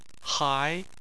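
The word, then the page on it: hai2: tone 2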